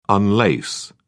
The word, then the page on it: unlace: British English